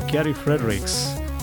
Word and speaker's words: Gary Fredericks: Welcome to DevN, episode number 52 with Gary Fredericks.